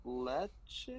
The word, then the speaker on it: Lecce: And then with 18th, I got Lecce, I think is how you pronounce it. – Yeah, I believe so, yeah.